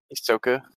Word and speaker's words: Hisoka: Is it Hisoku, right? Is that how you say his name? – Hisoka.